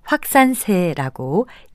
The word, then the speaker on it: 확산세: 결론부터 말씀드리면 이 단어는 마지막 음절을 확산세처럼 된 소리로 발음하지 않고요. 확산세라고 예사소리로 발음하는 것이 맞습니다.